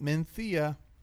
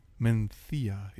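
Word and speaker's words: Mencía: So I would like to say that Mencia, as it's properly pronounced in España, where I am perfectly welcome, unlike some places in the United States that have court orders against me, in my opinion. Menthia is lovely, and I know that the Pinophile loves it.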